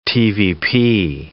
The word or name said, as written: TVP